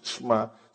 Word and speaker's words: Shema: Pero la pronunciación es Shema.